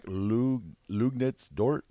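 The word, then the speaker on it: Luguentz Dort: Do you know how to pronounce it? – It's like Lugnitz Dort. – Yeah, nice job.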